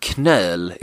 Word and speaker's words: knöl: K-N-O-L. English really lacks words that start with K-N, where you pronounce the K.